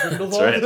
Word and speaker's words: Grindelwald: Pour one out for that excellent pronunciation of Grindelwald. – That's right. Not Grindelwald. It sounded Grindelwald. Like the bad guy in Men in Black at the end.